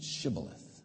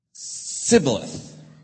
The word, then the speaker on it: shibboleth: Just say the word shibboleth. Sibboleth off with his head. You're dead. Because they couldn't pronounce it. Because this new pronunciation of sh was there in the east, but it hadn't spread to the west.